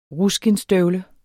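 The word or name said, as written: RUSKINDSSTØVLE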